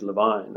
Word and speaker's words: Lewin: We're talking about Lewin's theory of change, but the correct way of pronouncing the name is Levine or Levine.